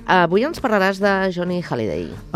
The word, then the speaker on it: Hallyday: Molts llocs se li deia Johnny Hallyday, però a França es deia la H muda també i es deia Johnny Hallyday.